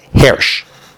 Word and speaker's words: Hirsch: That's the way people pronounce it, Hirsch. He says with a segol, but that's not relevant to us.